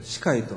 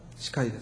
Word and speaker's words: 死海: そしてそのヨルダン川の行き着く先がもう一つの湖、死海、死の海と書いて、まあ、死海と読みますけれども、死海です。